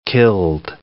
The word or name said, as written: killed